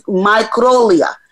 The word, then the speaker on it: microglia: microglia.